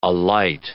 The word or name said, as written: alight